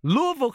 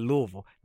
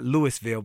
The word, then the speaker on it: Louisville: Well, I was in Louisville, Kentucky. You know, I've been there when you can say it like that, Louisville, not Louisville, but Louisville.